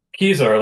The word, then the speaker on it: Kisar: Kisar, like you know where my keys are.